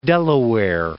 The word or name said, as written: De La Warr